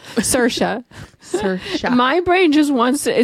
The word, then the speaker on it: Saoirse: Saoirse. Saoirse, damn it. – Saoirse Ronan.